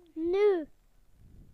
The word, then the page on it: now: British English